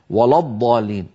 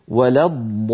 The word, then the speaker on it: ولا الضالين: أيضا هنا لما نقول غير المغضوب عليهم ولا الضاء، فاللام في ولا جاورت الضاد المفخمة، فلعلها تتأثر بها فينطقها القارئ خطأ ولا الضالين ولا الضالين، هذا لا يصح أبدا، بل عليه أن يقول ولا الضالين.